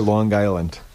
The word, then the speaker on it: Long Island: You have to say Long Island. – It's Long Island? – One word. – Hard G.